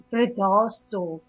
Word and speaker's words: Bredasdorp: Um, actually lives in Bredesdorp. How did you say you pronounced it? It's Bredasdorp. Bredasdorp, oh, okay.